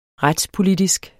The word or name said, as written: retspolitisk